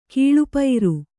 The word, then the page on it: ಗಳಪೂರ: kannada